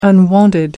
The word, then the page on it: unwonted: American English